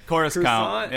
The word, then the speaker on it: Coruscant: Coruscant.